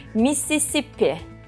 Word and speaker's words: Mississippi: İsmini telaffuz edemezsem lütfen kızmam. Mississippi. – Evet. – Doğru mu telaffuz ettim? – evet.